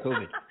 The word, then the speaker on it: Chauvin: Derek Chauvin. Derek Chauvin.